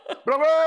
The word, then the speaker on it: Bravo: Say it right. – Bravo. Bravo. – You got it. – Put a lot of space in it.